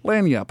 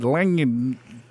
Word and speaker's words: lagniappe: yeah, because I would have said lagniappe. – Gappy. I, yeah, I would have said yeah, uh, la, lagniappe. – Lagniappe. – Uh. – I guess the G is silent.